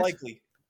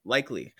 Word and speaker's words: Likely: Likely. – Likely. That is likely the correct pronunciation of Isaac Likely.